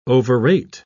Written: overrate

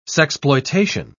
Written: sexploitation

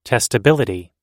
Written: testability

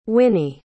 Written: wynnie

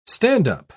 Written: stand-up

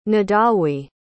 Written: Nidawi

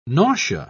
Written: nausea

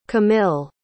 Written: camile

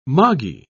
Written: moggy

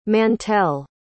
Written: Mantell

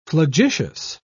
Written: flagitious

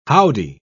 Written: howdy

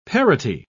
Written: parity

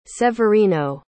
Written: severino

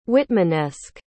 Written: whitmanesque

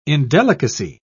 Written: indelicacy

